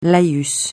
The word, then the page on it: laïus: francais